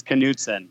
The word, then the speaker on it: Knudsen: Am I saying that right without the K? – No, the K you use, it's Knudsen.